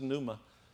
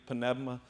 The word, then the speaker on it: pneuma: Y'all, the Greek word there for wind is the Greek word pneuma, which literally means spirit. Some southern translations is pneuma. But in Greek, how you say it in Greek, pneuma, and literally means, the Spirit blows wherever he wishes.